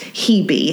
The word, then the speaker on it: Hebe: Now, I want to pause for a second because I was able to verify, yes, it is pronounced Hebe. Because I looked it up and I saw, you know, Hebe, and I was hoping that was it. And I asked Nathan, and he said, yeah, I think that's possibly Hebe, like Phoebe.